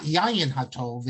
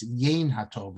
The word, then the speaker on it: Yain HaTov: It's often mispronounced as, I don't know if you're seeing it correctly, I'm seeing it reversed, but it's not Yayin HaTov, it's Yain HaTov.